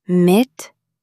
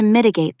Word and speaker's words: mitigate: You might not stop a crisis completely, but you can take steps to mitigate the damage. Stress the first syllable. Mitigate. Try saying it. Mitigate.